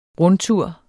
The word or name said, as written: rundtur